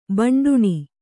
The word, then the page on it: ಭಂಡಣ: bhaṇḍaṇa